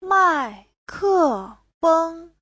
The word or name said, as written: mai4ke4feng1